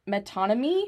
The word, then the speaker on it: metonymy: I don't know how to pronounce this word, and it looks like it's metonymy? – Metonymy. – Metonymy. – Yes, you're right.